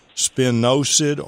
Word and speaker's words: spinosad: Spinosad, S-P-I-N-O-S-A-D, spinosad or spinosad, some people pronounce it.